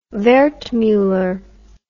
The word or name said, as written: wertmuller